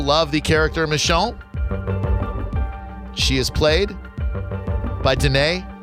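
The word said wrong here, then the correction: Danai: Gurira, and I hope I pronounced that right. Did I get that right, Danae? – Deny. – Of course, deny.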